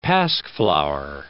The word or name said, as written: pasqueflower